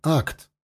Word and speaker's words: ACT: Мы официально произносим акт как одно слово – акт, а не как аббревиатуру ACT.